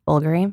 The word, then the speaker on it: Bulgari: Bulgari.